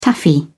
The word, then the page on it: taffy: British English